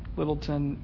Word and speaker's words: Lyttleton: Okay, if you want to look him up, spelled L-Y-T-T. L-Y-T-T-L-E, Littleton, T-O-N.